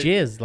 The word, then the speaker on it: Ghislaine: I'm telling you, Ghislaine. Right. Jizz, like the word jizz.